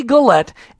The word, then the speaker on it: Gullett: Yeah, I would have said it the opposite way with the emphasis on the different syllable. All right, Courtney Gullett, and she's got a passion for a certain country we want you guys to hear about.